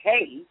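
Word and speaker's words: He: The Hay, and that's spelled H-E, Hay,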